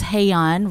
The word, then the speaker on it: Hye Yun: Oh, so my name is spelled a little funny, and I always say it's Hey-yon, rhymes with hey gay yon.